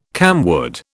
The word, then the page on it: camwood: British English